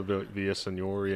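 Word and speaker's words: Villaseñor: Well, who's the other, the one girl, Vanessa Senor? Did I say that right? – Vanessa Villasenor, yeah.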